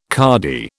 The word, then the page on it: cardy: British English